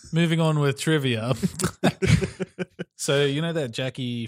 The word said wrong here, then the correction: Joaquin: Phoenix or however you say his name? – Joaquin Phoenix.